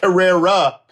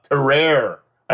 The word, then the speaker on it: Carrera: We had Tia Carrera, not Carrera, Carrera. I've been pronouncing her name wrong for 30 years.